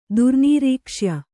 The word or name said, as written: ದುರ್ನೀರೀಕ್ಷ್ಯ